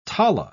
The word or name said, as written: tala